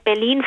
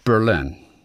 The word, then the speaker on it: Berlin: Du weißt, wie Deutsche Berlin aussprechen im Englischen, ne? – Berlin. – Nee, die sagen Berlin. – Ah, die sagen Berlin. Berlin. – Ja, weil sie denken, aha, im Deutschen ist es Berlin. – Ja. Also muss ich es im Englischen bestimmt umdrehen. – Ja, hätte ich auch tatsächlich vermutet. Ich finde, dass das auch eine gewisse Logik hat tatsächlich, weil im Englischen diese kurzen Wörter gerne auf der ersten Silbe betont werden.